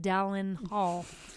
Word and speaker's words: Dallin Hall: Dallin Hall, who we mentioned a lot of times, it's pronounced Dallin Hall, by the way.